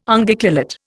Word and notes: Unguiculate: Indian

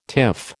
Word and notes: tiff: American English

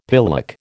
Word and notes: pillock: American English

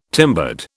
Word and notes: timbered: British English

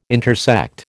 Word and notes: intersect: American English

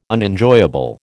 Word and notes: Unenjoyable: American English

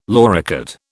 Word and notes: loricate: British English

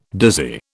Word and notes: dizzy: British English

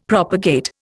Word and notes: propagate: Indian

